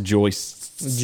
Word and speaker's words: joists: And we went from no floors with rotten floor joists to... – And joist. It's pronounced joists. – Joists. – Yeah. S-T. – S-T-S.